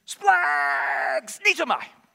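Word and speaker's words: splags. Needs-o-my (σπλαγχνίζομαι): It's a really funky word, and it is pronounced basically splags. Needs-o-my.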